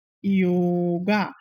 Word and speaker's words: yoga: it becomes Y-O-G-A, you know, and so obviously people sound it yoga, but actual pronunciation is yoga, and then also asana instead of asana.